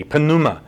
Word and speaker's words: pneuma: And the Koine Greek word, which I believe he most certainly used or brings up in his conversation, is pneuma, P-N-E-U-M-A. The debate is the Greeks may have actually pronounced the P, pneuma. We don't. We pronounce it pneuma.